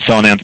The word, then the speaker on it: Sonance: Well, people have their own pronunciations, so Sonance and Sonance, they both work.